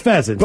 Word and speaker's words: pheasant: Oh, that's pheasant. – But without the The silent H, right?